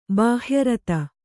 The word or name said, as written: ಬಾಹ್ಯರತ